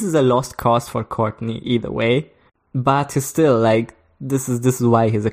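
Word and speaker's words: Cortnay: I'm always pronouncing him like the same way as you would pronounce Courtney Cox or Courtney Love. – Yeah, I think it's just Courtney. Just Courtney Penrose.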